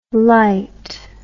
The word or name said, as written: lyte